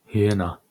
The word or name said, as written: hyena